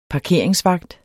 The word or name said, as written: parkeringsvagt